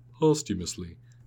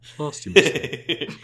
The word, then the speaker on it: posthumously: Granted, I found out I can't read the word posthumously, but I had to go and go, okay, Google, you tell me how to say it. Posthumously. Okay, say that again so that I can say it as a human. Posthumously.